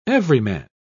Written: everyman

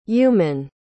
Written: eumann

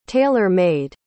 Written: tailor-made

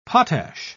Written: potash

